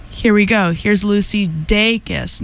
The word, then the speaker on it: Dacus: I recently discovered that her name is pronounced Dacus and not Dacus, which is what I thought it was.